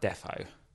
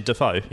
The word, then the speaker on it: Defoe: My friend said he hates it, really hates it when people get pronounced footballers' names wrong. I was like, Defoe. – No, so as in Defoe? – Yeah.